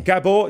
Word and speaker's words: Gabor: Have you come across Gabor Maté's work? – Never heard those syllables put together before. – You would absolutely love Gabor Maté. – Gabo Maté. – Gabor, G-A-B-O-R. – Got it. – Maté, M-A-T-E.